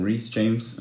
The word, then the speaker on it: Reece James: Mit James, das ist ja die Schwester, das kann man schon sagen, vom Reece James, wenn ich den Namen richtig ausgesprochen habe, von Chelsea. Ist richtig, ja.